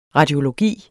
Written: radiologi

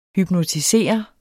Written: hypnotisere

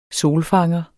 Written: solfanger

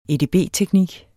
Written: edb-teknik

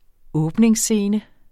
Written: åbningsscene

